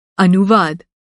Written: अनुवाद

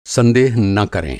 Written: संदेह

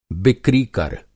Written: बिक्रीकर

